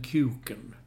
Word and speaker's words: KEWKEN: Men mellan varje Visa så står det ett ord som inte kan uttalas på annat sätt än kuken. Mm. Visa kuken så här på hela arenan. Och det står alltså K-E-W-K-E-N.